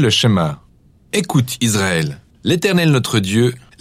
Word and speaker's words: Shema: Le premier mot du Shema est écouter ou entendre, prononcé Shema en hébreu.